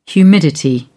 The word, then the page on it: humidity: British English